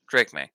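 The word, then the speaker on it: Drake Maye: Derek May. Is it Derek May? Did I say that right? – Drake May.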